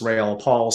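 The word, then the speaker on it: Raoul Pal: I think I agree with what I heard Raoul, technically pronounced Rail Paul, say, and I've interviewed him a couple of times.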